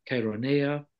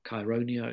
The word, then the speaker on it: Chaeronea: He defeated a rare alliance of the Athenians and their rivals, the Thebans, at the Battle of Chaeronea, or Chaeronea, it's pronounced in different ways.